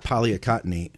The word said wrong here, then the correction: picolinate: I'm not going to say it. Maybe I did say it right. – Picolinate. – Oh, yeah. Picolinate. Thank you.